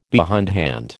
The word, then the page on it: behindhand: American English